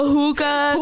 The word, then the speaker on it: hookah: Did I say it right? – No, you said not a hooker, like prostitution. – No, I had a hookah, Kratom, and Kava lounge. Not a hooker lounge.